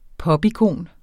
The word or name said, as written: popikon